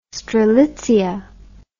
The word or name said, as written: strelitzia